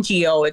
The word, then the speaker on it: Ngo: His name is Andy No, I think it's pronounced as an NGO.